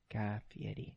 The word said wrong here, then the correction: Fieri: It's Fieri. – Look up his name. – Okay. – There's no D or T in that name. It looks like Ferrari. It's Fieri. But it's pronounced with an Italian accent.